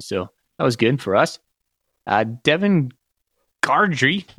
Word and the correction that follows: Gandy: Gordry, am I saying that right? I can't read my own handwriting. Gandy. Gandy. There is no R in there.